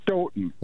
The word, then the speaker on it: Stoughton: You actually, the second time you said it right, Stoughton. They pronounce it Stoughton. – Like Houghton, but Michigan.